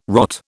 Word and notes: rot: British English

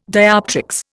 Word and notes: dioptrics: Indian

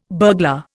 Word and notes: burglar: Indian

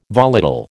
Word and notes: volatile: American English

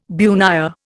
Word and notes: bunya: Indian

